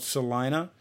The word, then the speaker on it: Celina: It's called Salina. It's spelled with C-E-L-I-N-A, and it's north of Dayton and south of Lima, which is where Joe Henderson is from, Lima, Ohio.